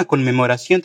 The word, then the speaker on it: conmemoración: Y la palabra conmemoración, que tiene dos consonantes ahí por el medio, es decir, la junción de una N y una M, y las dos deben ser pronunciadas sí o sí.